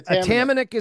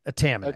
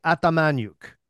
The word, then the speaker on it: Atamanik: So Atamanik is how you say it. Atamanik. – Okay. – Now that's Canadianized. So it's Ukrainian. So it should be Atamanyuk. That's the way it should be pronounced.